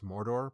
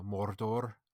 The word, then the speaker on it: Mordor: I did like that she pronounced Mordor properly, you know, Mordor with the rolled R's, which I can't even quite do correctly.